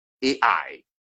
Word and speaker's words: Ai: Ai is spelled A plus I, Ai.